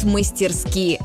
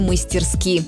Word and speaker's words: мастерски: Хоть седые его виски, он танцует мастерски. Этот шуточный стишок придумали специально для того, чтобы мы запомнили правильное ударение в слове «мастерски».